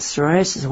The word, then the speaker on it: psoriasis: And I don't know about psoriasis. What is that? – Well, here... – That's a funny word. You don't pronounce the P, right? How do you say that? – Psoriasis with a silent P.